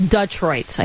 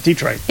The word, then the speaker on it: Detroit: Texter says it's pronounced Dutroit. I haven't ever heard that before. – Dutroit. – D-U-H. – No, Dutroit. Detroit. – It's D. – It's Detroit. Yeah, you got to go with the Ditka pronunciation of it. It's Detroit.